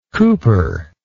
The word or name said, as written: Cowper